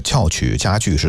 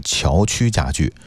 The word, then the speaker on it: 翘曲加剧: 主持人，我们纠正一个读音，刚才我念的那个翘曲加剧是有问题的，是错的，应该是翘曲加剧。